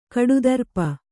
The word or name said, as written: ಕಡುದರ್ಪ